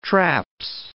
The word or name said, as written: traps